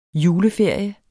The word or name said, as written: JULEFERIERNES